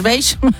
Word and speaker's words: beige: No, eso no es marrón, eso es beige.